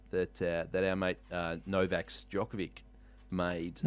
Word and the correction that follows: Djokovic: Novak who? – Djokovic. – Djokovic. – Did sure. – I went with a hard C there, didn't I?